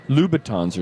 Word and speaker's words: Louboutin: But Lou Lou Louboutin, Louboutins are shoes. – That's correct. – Louboutin. Right. Am I saying that correctly? – Yes.